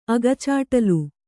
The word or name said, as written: ಅಗಚಾಟ್ಲು